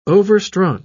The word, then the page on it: overstrung: British English